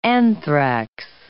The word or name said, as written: anthrax